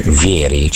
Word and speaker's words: Vieri: Ecco, perché insomma ne abbiamo parlato poco di questo tema, per cui si dice Vieri.